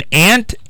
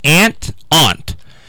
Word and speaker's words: aunt: Pronunciation of Ant varies between Ant and Ant-Aunt. And depends heavily on location. Ant, used about 75% of speakers in the United States, particularly in the West, Midwest, and South.